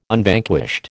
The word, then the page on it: Unvanquished: American English